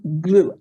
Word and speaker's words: Gloo: Is it glue? glue. I'm not good at pronouncing glue. Yeah, G L O O. Yeah, uh-huh.